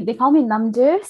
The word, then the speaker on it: Namdol: Yeah, they call me Namdu. So that's how you say it in Tibetan, but when you write it out in English, yeah, there is that M and that strong L sound that we don't really pronounce in Tibetan.